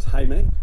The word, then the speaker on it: Jaime: Lenny introduced me to this Hispanic guy whose name was Jaime, which is how you pronounce Jamie in Spanish.